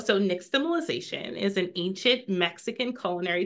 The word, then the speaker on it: nixtamalization: Nixtamalization.